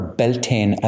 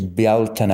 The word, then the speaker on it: Bealtaine: Baal was the name where Beltane, as it's pronounced in the United States, Beltane, its Irish name is Bealtaine or Baaltaine, which comes from the Tine, meaning fire, and Baal, the god of fire.